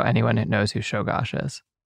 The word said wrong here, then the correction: Czolgosz: That's why people know how to pronounce Czolgosz. – Oh, whoops. Czolgosz.